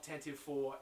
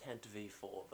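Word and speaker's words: Tantive IV: Tantive 4, and it was just, oh, God. – I mean, technically it's pronounced Tantive 4, but...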